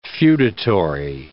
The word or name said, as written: feudatory